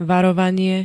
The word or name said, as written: varovanie